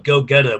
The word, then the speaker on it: Gogeta: When they form together in the newest movie, it's spelled Gogeta, but it's Gogeta.